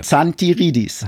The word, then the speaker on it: Zantiridis: Wie wird der Nachname nochmal ausgesprochen von dir? – Zantiridis.